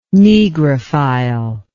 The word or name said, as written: negrophile